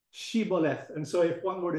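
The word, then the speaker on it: Shibboleth: If they say Siboleth, it means they are from Ephraim, because the Ephraimites, for some reason, were unable to pronounce the H.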